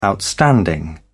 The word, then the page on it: outstanding: British English